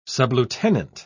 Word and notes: sublieutenant: British English

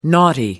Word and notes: naughty: American English

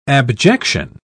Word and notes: abjection: British English

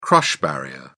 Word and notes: crush barrier: British English

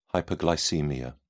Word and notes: hyperglycaemia: British English

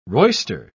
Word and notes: roister: British English

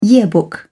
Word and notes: yearbook: British English

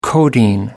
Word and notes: codeine: American English

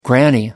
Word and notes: granny knot: American English

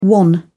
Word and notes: wan: British English

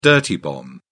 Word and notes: dirty bomb: British English

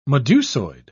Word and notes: medusoid: British English

